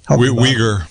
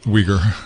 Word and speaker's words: Uyghur: Uyghur. – Yes, thank you. – Uyghur.